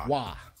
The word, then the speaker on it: Roy: Wah. – Right. It's spelled Roy. Patrick Wah was the goalie.